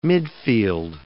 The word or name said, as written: midfield